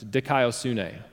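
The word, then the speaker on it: dikaiosune (δικαιοσύνη): It's dikaiosune.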